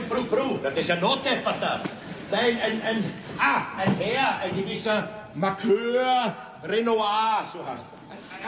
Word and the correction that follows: Marquis: Ah, du meinst den Marquis Renoir? – Ja, was schreien Sie?